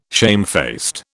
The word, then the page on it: shamefaced: British English